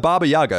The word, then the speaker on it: Baba Yaga: Become the Baba Yaga. Am I saying that right? – Yeah.